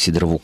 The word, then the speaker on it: сидорову: Ну, а по поводу «драть как сидорову козу», кстати, на самом деле действительно ударение идет на первый слог, хотя словарь подчеркивает, что возможно и смещение ударения.